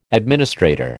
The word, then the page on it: administrator: American English